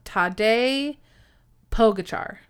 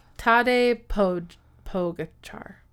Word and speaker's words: Tadej Pogačar: So I called him Tadej Pogačar. And I looked it up on how to pronounce it in real life and how it's Tadej Pogačar.